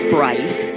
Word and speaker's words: Brice: What is the correct pronunciation? – So the correct pronunciation is Bryce, and the correct spelling is B-R-I-C-E.